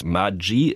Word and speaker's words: Maggi: Eigentlich müsste das Ganze Maggi ausgesprochen werden, italienisch. Denn Julius Maggi ist zwar in der Schweiz geboren, aber als Kind eines italienischen Einwanderers.